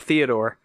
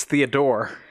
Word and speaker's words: Theodore: Those of you who didn't get to see that, Theodore, and by the way, his name is not Theodore. It's Theodore. It sure. – Seems. Emphasis on that last syllable.